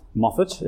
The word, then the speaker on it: Moffat: I have a really annoying surname, Moffat.